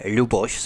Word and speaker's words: Ľuboš: Vy vlastně nejste Luboš, ale Luboš s Mekčeňom na začátku, abych to tedy správně vyslovil, protože my v češtině ten Mekčeň nemáme, ale jste Luboš, že?